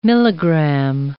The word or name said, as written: milligram